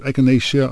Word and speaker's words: echinacea: It's also called echinacea, but echinacea, I think, is the proper pronunciation. I've heard people say echinacea.